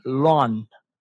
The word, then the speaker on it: Loan: Fíjate, su nombre real no es Kelly Marie, ella es de padres vietnamitas y su nombre pues original es Lon, Loan se escribe, Tran Lon, Pero pues se cambió su nombre para hacer algo más artístico, más americano, ¿no?